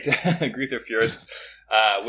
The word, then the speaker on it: Greuther Fürth: Now an American who will not be in a top league, but a second league, Julian Green signed with Bundesliga 2 side Greuther Fürth. Is that how you say it? Do you know? You took many years of German, and I didn't. Yes. Great. Greuther Fürth, which...